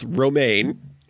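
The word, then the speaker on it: Romijn: Dwayne Johnson, Octavia Spencer, John Cho, Will Wheaton, Rebecca Ramen. – Not ramen noodles, romaine.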